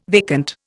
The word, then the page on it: vacant: Indian